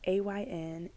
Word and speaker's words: Ayn: It's Ayn, and then last name Rand.